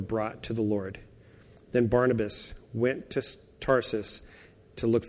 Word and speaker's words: Barnabas: And the news came in verse 22 to the ears of the church of Jerusalem, and they sent Barnabas.